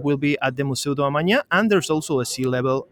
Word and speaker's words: Museu do Amanhã: And like I said, today we have the party at the Museu do Amanhã. Is that correct? – Yes, that was a perfect pronunciation.